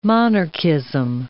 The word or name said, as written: monarchism